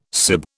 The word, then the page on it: sib: British English